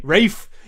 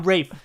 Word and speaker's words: Ralph: Because it's fucking spelled Ralph, and it's pronounced Ray. – Rafe. Yo, I'm Rafe Fiennes. – Oh, yeah, Rafe just the Fiennes. L and the P and the H are silent.